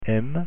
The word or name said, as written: m